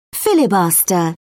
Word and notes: filibuster: British English

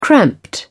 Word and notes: cramped: British English

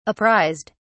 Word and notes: apprised: British English

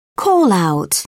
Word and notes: call-out: British English

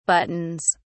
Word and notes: buttons: British English

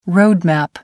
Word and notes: road map: American English

